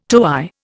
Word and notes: toey: Indian

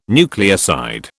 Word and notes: nucleoside: British English

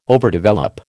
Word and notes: overdevelop: American English

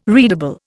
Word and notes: readable: Indian

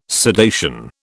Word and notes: sedation: British English

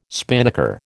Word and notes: spinnaker: American English

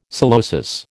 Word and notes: psilosis: American English

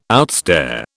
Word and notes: outstare: British English

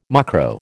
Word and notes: mucro: American English

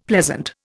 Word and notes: pleasant: Indian

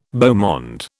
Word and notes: beau monde: British English

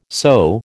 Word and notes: sew: American English

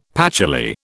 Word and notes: patchouli: British English

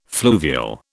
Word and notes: fluvial: British English

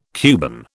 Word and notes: Cuban: British English

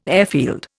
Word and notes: airfield: Indian

